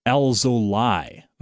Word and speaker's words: Alzolay: But we've now had the definitive pronunciation for the Cubs starting pitcher tonight. Adbert Alzolay, according to Len Casper, who is the Cubs television broadcaster. I've never heard it pronounced Alzolay, but that is the official pronunciation,